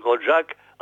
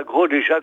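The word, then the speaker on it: Grolejac: Et comme je disais à l'instant, que l'on prononce Grotjac et non pas Grolé-Jac comme ça s'écrit.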